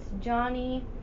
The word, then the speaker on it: Jawny: I think it's Johnny, but it's spelt wonky.